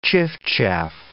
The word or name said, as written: chiffchaff